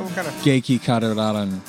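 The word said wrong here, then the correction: Geki Kara (激辛): It's another one called like Geki Katarun or something. – Geki Kara.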